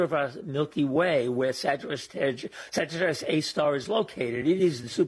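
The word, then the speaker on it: Sagittarius A*: Sagittarius A star. Did I say it correctly? – Yes, that's correct. It's A with an asterisk, but it's pronounced A star.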